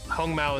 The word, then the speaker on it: Hong Mao: So, one thing I want to talk about, since you brought up the Hong Mao, Hong Mao is obviously the Mandarin pronunciation, but the the Taiwanese or the Hokkien pronunciation is Hangmo, Hangmo.